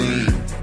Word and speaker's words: zine: Don't be getting twisted. It's pronounced zine.